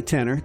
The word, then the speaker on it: Tenor: And at the beginning of that set was Jimmy Tenor. Jimmy spelled like Jimi Hendrix, only without the Hendrix, just the Jimmy part. And tenor like a tenor saxophone, or, you know, like a tenor in a choir. Not like a $10 bill you find down the street. No, that's a tenor. T-E-N-N-E-R.